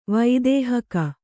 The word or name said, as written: ವೈದೇಹಕ